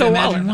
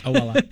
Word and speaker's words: Owala: I think it's Owala. I think you just don't overthink it. I think you just get it out. – Yeah, Owala.